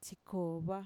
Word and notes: txikova: Mixtec

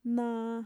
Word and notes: naa: Mixtec

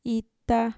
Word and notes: ita: Mixtec

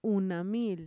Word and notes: unamiil: Mixtec

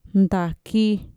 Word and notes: ntaki: Mixtec

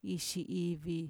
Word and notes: ixiivi: Mixtec

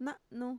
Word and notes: na'nu: Mixtec

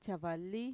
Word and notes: txavali: Mixtec

